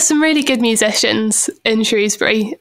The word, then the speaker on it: Shrewsbury: A town called Shrewsbury, which... – Oh, it's Shrewsbury, yeah, okay. – A lot of people pronounce it Shrewsbury. – Shrewsbury. – Yeah, but it is Shrewsbury.